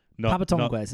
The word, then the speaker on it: Papa Tongues: Papa Tongues is how you... Not paper tongues. That's a very silly way of saying those two words.